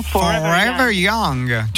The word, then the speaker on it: Forever Young: La chanson Forever Young.